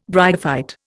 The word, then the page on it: bryophyte: Indian